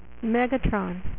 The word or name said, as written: Megatron